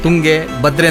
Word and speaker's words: ತುಂಗೆ ಭದ್ರೆ: ಆದರೆ ಒಂದಿಷ್ಟು ನಾವು ಉಚ್ಚಾರಗಳನ್ನು ತಪ್ಪಾಗಿ ಮಾಡ್ತಾ ಇದ್ವಿ. ಅದಕ್ಕೆ ಉದಾಹರಣೆ ತಾವೇ ಹೇಳ್ದಂಗೆ ತುಂಗೆ ಮತ್ತು ಭದ್ರೆ. ಸೊ ಇವತ್ತಿಂದ ಆದರೂ ನಾವು ಅದನ್ನು ತುಂಗೆ ಭದ್ರೆ ಅಂತ ಕರೆಯೋಣ. ಭದ್ರ ಅಂದರೆ ಗಂಡು, ಎಲ್ಲರಿಗೂ ಗೊತ್ತಿದೆ. ಸೊ ಅವು ಹೆಣ್ಣು, ಹಾಗಾಗಿ ತುಂಗೆ ಭದ್ರೆ, ತುಂಗೆ ಭದ್ರೆ ಎರಡು ಸೇರಿದ ಮೇಲೆ ತುಂಗೆ ಭದ್ರೆ ಅಂತ ಕರೆಯೋದು ಇವತ್ತಿಂದ ಆದರೂ ರೂಢಿ ಮಾಡ್ಕೊಳ್ಳೋಣ ಅಂತ.